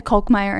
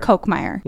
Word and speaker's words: Kolkmeyer: Morgan, you just told me that the pronunciation of your last name is Kolkmeyer.